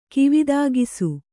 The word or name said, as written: ಕಿವಿದಾಗಿಸು